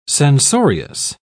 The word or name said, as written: censorious